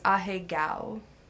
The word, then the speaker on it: ahegao: It looks like the proper pronunciation is ahegao. – Ahegao.